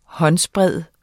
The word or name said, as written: håndsbred